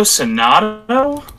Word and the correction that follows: Xanadu: Tokyo and then X. No, yeah, X A N A D U. – Oh my god. Bro, that's Xanadu. Yes, Tokyo Xanadu is a Falcom RPG.